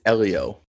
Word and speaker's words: Helio: How do you pronounce that again? Say it again. Julio Castroneves? – I think it's Elio.